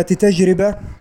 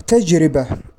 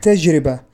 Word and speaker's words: تجربة: الخطأ الأخير الذي يقع كثيرا في وسائل الإعلام: نطق كلمة تجربة بضم الراء، وهذا خطأ. والصواب تجربة بكسر الراء، فلا تقول تجربة بل قل تجربة،